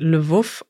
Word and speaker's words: Lwów: Letztes Mal haben wir eine Nachricht vorgelesen aus einer ukrainischen Stadt mit dem Namen Lviv. Und ich habe dir aber gesagt, das wird Lwów ausgesprochen.